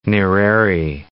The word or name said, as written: Nyerere